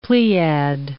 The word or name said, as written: pleiad